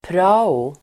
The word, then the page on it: prao: [pr'a:o]